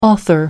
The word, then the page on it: author: American English